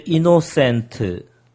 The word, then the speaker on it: innocent: He is innocent compared to his brother. – 네, 그런데 innocent의 발음이 너무 마리오다워서 네. 다시 한번 할게요. 네. 자, 따라해보세요. Innocent. – Innocent. – Cent가 아니라 innocent.